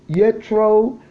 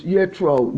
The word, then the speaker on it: Yitro: Number 17 is called Yetro, and it's spelled Y-I-T-R-O. It's Yetro. Let me spell it again. It's Y-I-T-R-O.